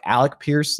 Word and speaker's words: Alec Pierce: Alec Pierce, I keep saying like Alex Pierce, so I have to like really pronounce like the C in his name. Like Alec Pierce, like getting more of the downfield looks.